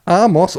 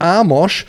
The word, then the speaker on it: Amos: Emsiger Selke, moin Strömer, kannst du bitte den guten Knipser, Betonung liegt auf gut, davon in Kenntnis setzen, dass Amos Pieper Amos und nicht Amosch sowie Nabi Keita Nabi und nicht Nabi Keita ausgesprochen werden.